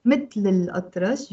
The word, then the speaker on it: Mittel il atrash: Mittel il atrash, you pronounce it with a liaison.